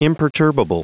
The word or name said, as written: imperturbable